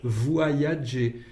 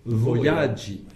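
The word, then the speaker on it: vojaĝi: Et donc, le verbe voyagi est un petit peu difficile à prononcer pour nous français. Nous avons tendance à dire voyager. Voyager. Et donc, dire voyagi, alors que c'est voyagi. Voyagi.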